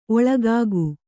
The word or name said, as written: ಒಳಗುಗೈ